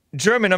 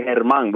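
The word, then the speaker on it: Germán: It's Spanish. You say Herman, but it's Herman. – Herman. It's spelled German. I say, yeah, just say German. It don't matter.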